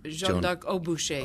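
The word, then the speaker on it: Jeanne d'Arc au bûcher: And so now this piece that's coming up on the 17th and 18th, Jeanne d'Arc de Boucher, did I say that correctly? – Jeanne d'Arc au Boucher. – Au Boucher, yes.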